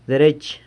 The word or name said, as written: derech